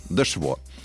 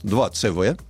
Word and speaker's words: 2CV: Это Citroën Дешво. Вот. Э, Citroën, который на русско-латинский манер часто произносится 2CV. Вот. На самом деле своим названием говорит ровно то, что слышит каждый француз.